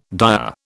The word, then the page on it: dyer: British English